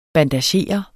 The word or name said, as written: BANDAGERES